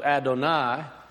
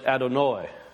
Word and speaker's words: Adonai: The way that came from was Adonai, or some pronounce it Adonoi. Some Jewish sects won't pronounce Adonai; they'll say Adonoi.